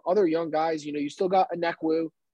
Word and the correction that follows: Okongwu: Got AJ Okongwu, thank you.